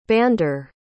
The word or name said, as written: bander